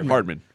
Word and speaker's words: Hardman: Hanman? Is that how you say his name? – Hardman. – Oh, I looked. That's an R.